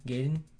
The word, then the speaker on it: Gaiden: I know they had Ninja Garden 2? – Gaiden 2, right? – Yeah. – Oh, well, I used to say that wrong all the time.